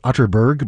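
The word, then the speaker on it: Atterberg: It's spelled Otterberg, but pronounced Otterberg, just in case you were Googling.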